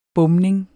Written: bomning

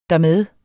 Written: dermed

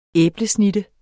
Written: æblesnitte

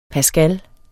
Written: pascal

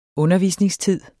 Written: undervisningstid

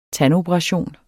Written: tandoperation

